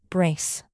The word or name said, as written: brace